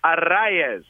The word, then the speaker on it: Arraez: The other part is, if you're going to phonetically present his name properly, there's an accent on the second A. – Right. – Which tells you that is the syllable that has the emphasis. So I'll say it slowly here. Luis Arraez.